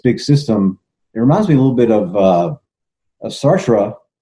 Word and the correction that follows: Sartre: And my southern tongue just butchered his beautiful name, Jean-Paul Sartre.